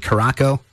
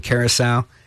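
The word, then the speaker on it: Curaçao: I'll never forget when I told my friend years ago that I was going to take a vacation in Caraco. I'm like, yeah, that little island down there in the Caribbean. He said, you mean Curacao?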